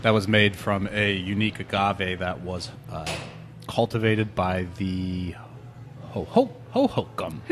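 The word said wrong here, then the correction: Huhugam: Hoo-hoo-gum. – Hoo-hoo-gum. That's the right way to say it.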